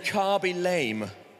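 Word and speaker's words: Khaby Lame: KB Lame. Carby. Okay, I said it wrong to start with, but that's fine because I'm not young, so that's all right. Okay, Carby Lame.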